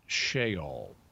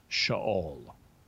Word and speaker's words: Sheol: That E in Sheol is a Hebrew short E. So you don't really pronounce it. It's not a long E. It's not Sheol. It's Sheol.